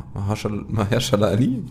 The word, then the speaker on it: Mahershala Ali: Man muss schon sagen, die dritte Staffel fand ich schon, war ich glaube mit Ma, Mahershala Ali.